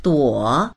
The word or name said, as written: duo3